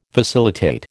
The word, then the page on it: facilitate: American English